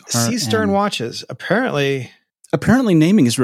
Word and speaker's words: Seestern: S T E R N. Seestern. Like a cistern, I suspect. Seestern. Like a. Si- could you guess? S-E-E.